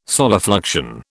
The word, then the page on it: solifluction: British English